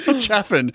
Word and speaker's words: Chaffin: C-E-A-N. – Cian. – Nice. I like it. Cian Chafin or Chaffin. How would you say that? – I'm sure she's Chafin. – Chaffin.